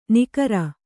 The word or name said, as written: ನಿಗರ